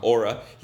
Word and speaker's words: aura: Steve Jobs에 대해서 얘기할 때 항상 Steve Jobs의 힘은 그 aura에 대해서 얘기할 때 aura에 대해서 얘기할 때 우리 맞는 발음은 aura, aura 아니고 aura.